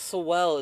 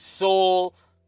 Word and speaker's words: Sowell: Dog, Thomas Sowell is one of my favorite people to listen to. It's Soul. Fuck you. I've said Sowell my whole life. I know it's pronounced Soul. I don't care.